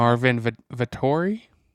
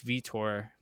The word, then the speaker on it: Vettori: Marvin Vettori? – Yeah, it's Vitor. Like, they pronounce it as Vitor or something like that.